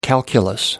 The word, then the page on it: calculus: American English